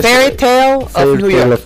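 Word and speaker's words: Fairytale of New York: Vamos a escuchar, y aquí le voy a pedir si me ayuda, si me corrige la pronunciación, Fire Tale of New York. Sí, debería ser. – Sí, eh, está eh, cerca. Eh, Fairy Tale of New York.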